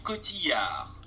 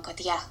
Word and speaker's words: Cotillard: Marion Cotillard. Cotilla. I'll say it like that. please don't. Let's see if we get like a more American pronunciation. – Marion Cotillard. In original French, Marion Cotillard.